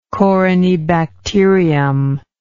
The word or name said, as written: corynebacterium